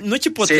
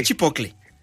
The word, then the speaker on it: chipotle: En chipocle, no es chipotle, sí. Es chipocle.